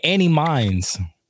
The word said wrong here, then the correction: Any Means: That ass. I didn't know it was Any Means.